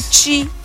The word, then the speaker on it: Twi: Or they call it Chi. – They call it Chi? – That's how it's pronounced.